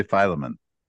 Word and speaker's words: Philemon: Do we want to say Philemon? – I'll yield to your pronunciation. – Okay. You will? Because I'm going to say Philemon.